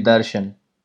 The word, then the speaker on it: Darshan: You can call me Darshan.